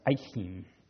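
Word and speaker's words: Achim: and it's actually pronounced Ikim,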